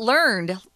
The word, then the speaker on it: learned: But we'll also use this um, as an adjective uh, and we'll pronounce it learned.